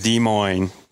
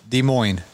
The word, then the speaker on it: Des Moines: Is that how you say it? – Des Moines. – Des Moines. – S is silent. – Des Moines. – Just Des Moines.